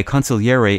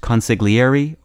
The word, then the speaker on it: consigliere: By the way, consigliere is also sometimes pronounced consiglieri or consigliere.